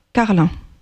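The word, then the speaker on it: Karlin: Alma Maximiliana Carlin. Je prononce bien ? – Oui, à la française, c'est ça.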